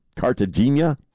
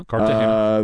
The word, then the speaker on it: Cartagena: Cartagena. – I'm a white guy. Colombia. – Uh-huh. Cartagena.